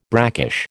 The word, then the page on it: brackish: American English